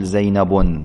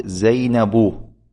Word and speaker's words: زينب: زينب كذلك لا تقول زينب، هذا خطأ، وإنما تقول زينب.